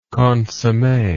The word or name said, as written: consomme